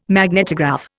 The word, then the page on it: magnetograph: Indian